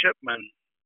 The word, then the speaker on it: Shipman: I'm Wayne Shipman. I usually have to spell it. It's S-H-I-P-M-A-N.